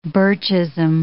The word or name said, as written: Birchism